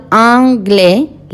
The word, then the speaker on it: anglais: En, como nasal, anglais. La AI ya sabemos que es E y la S no suena.